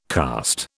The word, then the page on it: karst: British English